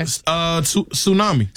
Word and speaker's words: tsunami: This has a silent letter in it. – Okay. – Tsunami. – Tsunami. – Tsunami. Yeah. – Tsunami. Sure does. – Now, some people might say tsunami. – Tsunami. – Tsunami. – Yeah. – Somebody might say that. No. They'd be wrong.